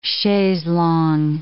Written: chaise longue

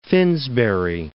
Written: Finsbury

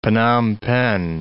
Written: Phnom Penh

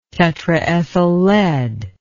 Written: tetraethyl lead

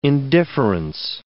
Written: indifference

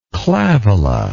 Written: clavola

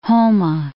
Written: Houma

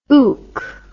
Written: ook

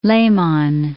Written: Lehmann

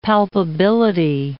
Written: palpability

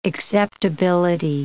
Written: acceptability

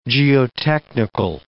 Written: geotechnical

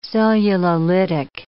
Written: cellulolytic

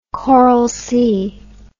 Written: Coral Sea

